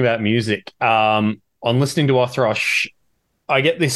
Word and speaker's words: Wothrosch: And I'm joined today by Phil from Wothrosch.